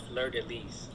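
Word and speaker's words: fleur-de-lis: it's pronounced fleur de lis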